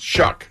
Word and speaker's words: Shough: His last name is spelled S-H-O-U-G-H, but it's pronounced Shuck.